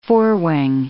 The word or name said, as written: forewing